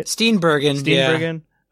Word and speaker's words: Steenburgen: how do you say her last name? Mary Steenburgen? Is that right? – Steenburgen. Yeah.